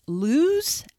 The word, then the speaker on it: Luz: But I watched, I believe it's pronounced Luz, L-U-Z.